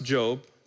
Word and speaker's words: Job: It's pronounced Job, not job.